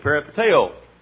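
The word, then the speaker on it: peripateo (περιπατέω): The word walk in the Bible is the word in the Greek peripateo. Uh, P-E-R-I, P-A-T-E-O is about the way we pronounce it.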